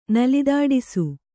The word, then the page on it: ನಲಿದಾಡಿಸು: Kannada